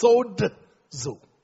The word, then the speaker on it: sozo: Now, the word sozo is the spelling S-O-Z-O, but it's actually pronounced sodzo. Sodzo.